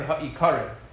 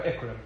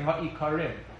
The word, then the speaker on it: HaIkarim: Rav Yosef Albo's Sefer HaIkrim is a Sefer which discusses, it's really, the correct way to pronounce it is the Sefer HaIkarim. Colloquially, in yeshivas, we call it the Sefer HaIkrim, but it's supposed to be the Sefer HaIkarim, which is the book of principles, the book of foundations.